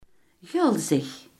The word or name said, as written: gulzig